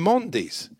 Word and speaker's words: Mendy: But they're both Mendys. – He calls them Mondys. I don't like Mondys, personally. – Well, you didn't have to worry about it. This Mondy. – It's a very French pronunciation.